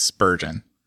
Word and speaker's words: Spurgeon: His name's Spurgeon. – Sorry. Which rhymes with sturgeon and surgeon.